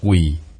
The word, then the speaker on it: quỳ: Cố có tấm lòng như vậy, quân tử khuể bộ, chữ này đọc là quỳ. Thanh thứ ba, ngày xưa là hai bước, hai chân trái phải bước hai bước nhỏ, gọi là một bước chân.